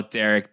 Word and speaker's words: Derryk: Very annoying way to spell Derek. D-E-R-R-Y-K. Derek.